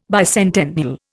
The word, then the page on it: bicentennial: Indian